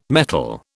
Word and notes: metal: British English

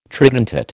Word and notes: tridentate: American English

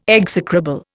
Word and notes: execrable: Indian

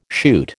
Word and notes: shoot: American English

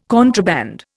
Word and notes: contraband: Indian

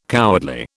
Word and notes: cowardly: British English

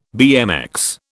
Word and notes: BMX: British English

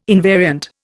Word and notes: invariant: Indian

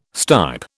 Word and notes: stipe: British English

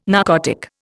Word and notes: narcotic: Indian

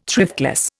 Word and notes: thriftless: Indian

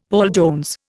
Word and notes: Paul Jones: Indian

